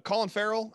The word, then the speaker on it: Colin Farrell: Colin Farrell, I believe is how his name is pronounced. I've always heard it pronounced that way. So I'm going to continue to.